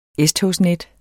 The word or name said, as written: S-togsnet